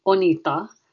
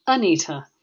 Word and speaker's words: Anita: And in Bengal, if one of my Indian relatives calls me, they tend to call me Onita, which is how it's pronounced in India. But in England, all my friends here call me Anita with a hard T.